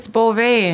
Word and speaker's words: Beauvais: Is it Bova or Bouvier? – It's Beauvais. It has a French. Even mm-hmm. my first name is Janelle. – Janelle? – Janelle Beauvais.